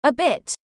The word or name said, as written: a bit